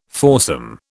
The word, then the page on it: foursome: British English